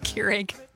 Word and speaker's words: Keurig: I'm not a fan of the Krieg. – Keurig? – Yeah. Yeah. – It's pronounced Keurig.